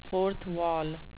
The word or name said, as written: fourth wall